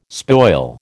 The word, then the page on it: spoil: American English